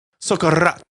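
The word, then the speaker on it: socarrat: The ingredients once added should not be disturbed, allowing for the delicacy known as socorrat, or crispy bottom rice layer, to form. You got to roll your R's because it's two R's put together.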